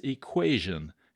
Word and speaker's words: equation: And the question was, what is the only common English word in which the J sound can be spelled with a T? And the answer is equation. Isn't that interesting? The only word ending T-I-O-N where we say J.